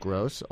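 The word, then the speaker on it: Groce: I've been corrected. It's G-R-O-C-E. – But indeed. – Mm-hmm. Pronounced Gross, author of Locks, Stocks, and Backstage Broadway.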